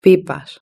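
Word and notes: pipas: European Spanish

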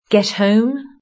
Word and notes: get home: British English

